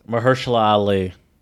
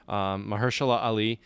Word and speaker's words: Mahershala Ali: Mahershala Ali. – Thank you. Yeah, I can never pronounce his name right. Mahershala Ali.